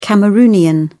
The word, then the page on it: cameroonian: British English